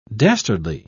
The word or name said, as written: dastardly